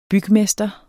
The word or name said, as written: BYGMESTRE